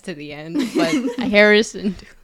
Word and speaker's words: Hera Syndulla: Hera Syndulla. – I said it right like 5 times and she still wasn't getting it. And then you called her Harrison once.